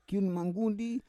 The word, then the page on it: kiun manggundi: Roon